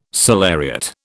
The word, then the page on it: salariat: British English